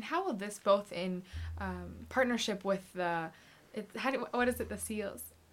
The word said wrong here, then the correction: Salus: Salus, you mean? – Salus, okay, I didn't know how to say it.